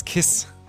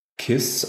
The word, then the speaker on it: Kiss: Äh, jein, also das ist ähm, aus dem Ungarischen eigentlich. Kiss, äh, K-I-S-S, ist im Ungarischen Kisch ausgesprochen und ähm, bedeutet, also ist dort ein geläufiger Name, so wie hier Müller oder Meier habe ich mir sagen lassen.